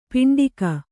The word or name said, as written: ಪಿಂಡಕ